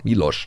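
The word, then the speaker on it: Miloš: Ich hoffe, ich habe es jetzt richtig ausgesprochen. Milos. – Ja.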